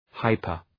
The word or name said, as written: hyper